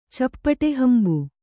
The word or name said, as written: ಚಪ್ಪಟೆಹಂಬು